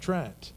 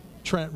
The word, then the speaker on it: Trent: And for me, it rhymes with my name. I hear it often, repent, Trent. Trent, repent. It kind of flows. And I say it to myself, well, Trent, you need to repent.